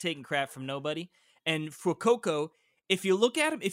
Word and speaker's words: Fuecoco: There's Fuecoco. – I think Fuecoco. – Yeah, like fuego, maybe Fuecoco. – That'd be a good pronunciation.